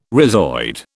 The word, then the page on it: rhizoid: British English